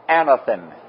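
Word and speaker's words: anothen: It can be pronounced something like anathen. Anathen.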